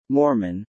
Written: Mormon

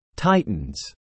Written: titans

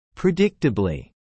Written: predictably

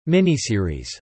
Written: miniseries

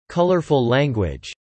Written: colourful language

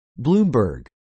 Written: Bloomberg

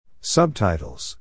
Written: subtitles

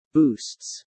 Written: boosts

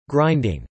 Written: grinding

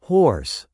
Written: hoarse

